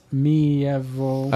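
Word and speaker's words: Miéville: By China Mieville, pronounced Mieville.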